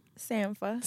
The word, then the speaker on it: Sampha: How do you say his name? – Sampha. – Sampha.